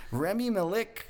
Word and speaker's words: Rami Malek: Rami Malek. I think I'm saying his name. I'm probably butchering his name. You got it.